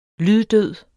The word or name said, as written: lyddød